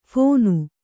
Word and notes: ಫಣ: phaṇa

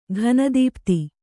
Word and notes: ಘನದೀಪ್ತಿ: ghana dīpti

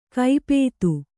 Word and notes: ಕೈಪೇತು: kaipētu

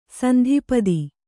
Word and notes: ಸಂತಪ್ತ: Kannada

